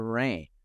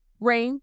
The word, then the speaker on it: Rhayne: so is you do I pronounce your name Raina or Rain? Rain, like the weather. Rain.